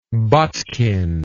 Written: botkin

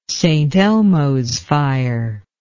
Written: St. Elmo's fire

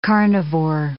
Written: carnivore

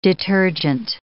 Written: detergent

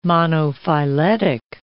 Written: monophyletic

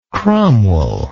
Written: cromwell

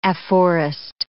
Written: afforest

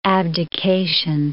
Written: abdication

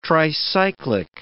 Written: tricyclic

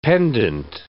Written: pendent

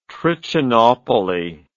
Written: Trichinopoly

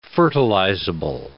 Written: fertilizable

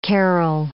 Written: carrel

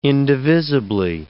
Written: indivisibly

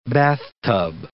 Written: bathtub

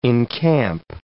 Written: encamp

